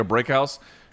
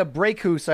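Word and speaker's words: Brækhus: Very quickly, I never know how to pronounce her last name, the Norwegian who looks like she has the, and I know you're going to make fun of this word, but I believe this is what it's called, a diphthong, which is when the A and the E are connected. Cecilia Breakhouse, she has a big fight coming up on DAZN.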